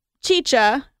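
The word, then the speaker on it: Xicha: So if you're over in that area, I would say Chicha Brewing is my favorite place in Salem.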